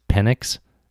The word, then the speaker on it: Penix: there's a quarterback for Indiana whose name is Penix, spelled Penix, like penis.